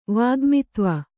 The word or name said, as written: ವಾಗ್ಮಿತ್ವ